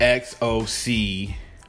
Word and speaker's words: Xochimilco: That's spelled Xochimilco. Xochimilco. So that sounds like a S.